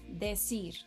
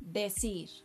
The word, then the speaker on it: decir: Decir. Decir.